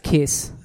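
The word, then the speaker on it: Kyuss: Josh Homme, I think, sued Caius. Caius broke up and then he formed Queens of the Stone Age. – It's pronounced Kiss.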